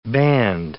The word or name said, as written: band